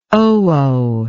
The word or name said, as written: oo